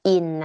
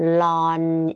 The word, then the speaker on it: ln: อันนี้ ln นะห้ามอ่านว่า in นะอ่านว่า ln